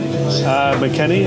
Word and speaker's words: McKennie: McKennie. Who, is that how you say his name? – McKennie, yeah.